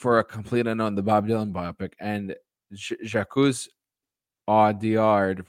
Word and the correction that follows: Jacques Audiard: for Emilia Perez. – Jacques Audiard. – Thank you. Sorry, my French is terrible.